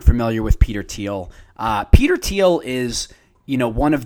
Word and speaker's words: Thiel: Some of you may not be, but it's Peter Thiel, T-H-I-E-L, not Thiel, which I incorrectly pronounced it at the beginning of my conversation with Max, but Peter Thiel, like the color.